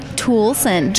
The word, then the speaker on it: Toolson: Toolson.